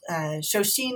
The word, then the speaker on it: shoshin: And some contemporary physicians also distinguish a kind of beriberi called shoshin. It's actually the Japanese pronunciation of chongxin.